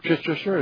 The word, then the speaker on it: Chichester: He was the dean of Chichester, or Chichester as they pronounce it.